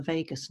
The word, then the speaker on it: vagus: the longest nerve in the body called the vagus nerve. It's spelled V-A-G-U-S, like Las Vegas, but slightly a different spelling.